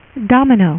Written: domino

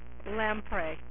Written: Lamprey

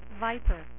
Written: Viper

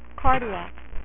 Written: cardiac

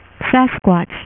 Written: Sasquatch

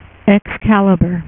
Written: excalibur

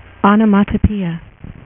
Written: Shazam

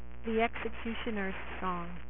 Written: X-Cutioner's Song

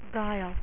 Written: guile

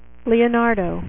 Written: leonardo

